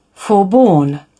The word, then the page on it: forborne: British English